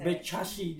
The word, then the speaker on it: Bekasi: Eh sama sama aku pengucapan Bekasi. Bekasi. – Enggak enggak salah.